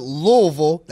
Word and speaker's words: Louisville: I can't wait for you to represent the University of Louisville. That's the correct pronunciation.